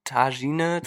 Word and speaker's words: Tagine: Also Tangine Tagine, ich, also T-A-G-I-N-E, könnt ihr selber mal googeln.